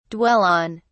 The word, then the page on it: dwell on: British English